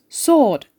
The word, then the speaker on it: sword: Who, whom, whose, sword ve answer kelimelerinde de W harfi telaffuz edilmez.